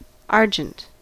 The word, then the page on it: argent: /ˈɑɹ.dʒɛnt/; /ˈɑː.dʒɛnt/